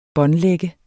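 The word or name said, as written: båndlagt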